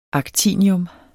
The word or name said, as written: actinium